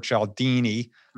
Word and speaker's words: Cialdini: So we talked about a little bit about Robert Cialdini in the beginning. I should be able to say his name a little bit better. – No, you got it right.